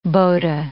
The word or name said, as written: bota